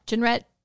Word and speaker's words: Jenrette: Representative John Jarrett, a Democrat from South Carolina, John Jarrett was one of the few who resigned before they, he was expelled from the House during the operation. Jenrette, um, did I say that right? Yeah, Jenrette